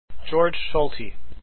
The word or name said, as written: Solti